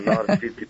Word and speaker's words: North City: Da li sam ja to dobro izgovorila? To pitamo organizator ovog festivala. Dobro jutro. – Dobro jutro. – Jel jeste? North City, jel tako? – Da, North City Jazz and Blues Festival.